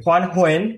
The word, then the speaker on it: Quan Huynh: Is that right? Good morning. Good morning. Quan Huen. It's it's close enough.